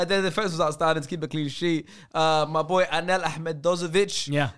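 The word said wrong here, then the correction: Ahmedhodžić: Ahmedozic, as it's pronounced, had a very good game.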